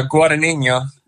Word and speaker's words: Guadagnino: Yeah, Guadagnino. – Guadagnino.